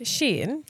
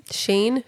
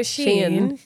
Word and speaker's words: Shein: Can I ask, on the theme of Shein, I believe it's pronounced Shein. – Sheen? Shine? – No, Shein.